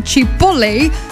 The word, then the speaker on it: chipotle: Dat smaakt dan weer anders dan bijvoorbeeld een chipotle. Chipotle, zeg ik het goed? Ja, en die is dan weer donkerrood.